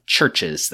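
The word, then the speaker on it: CHVRCHES: I will be joined by one of my favorite musical artists, Lauren Mayberry, the lead singer of Churches. That is Churches spelled with a V, C-H-V-R-C-H-E-S.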